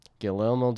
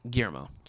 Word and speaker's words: Guillermo: Guillermo del Toro is debuting this week. Did I say that wrong? – Guillermo. – Guillermo del Toro.